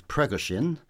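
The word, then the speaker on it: Prigozhin: So The Sun's got a nice story about, I'm going to massively mispronounce this, Pregoshin.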